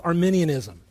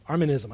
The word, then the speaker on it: Arminianism: On the other side, you have another camp, and that's called Arminianism. Arminism, I always mispronounce it.